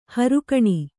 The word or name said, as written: ಹೊರಗಣ